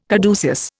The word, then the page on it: caduceus: Indian